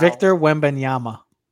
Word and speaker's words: Victor Wembanyama: Victor Wembanyama. – V-I-C-T-O-R.